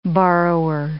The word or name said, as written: borrower